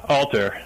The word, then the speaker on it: ALTR: I'm not sure exactly how you pronounce it. I guess it's spelled A-L-T-R, correct? – Yeah, Alter.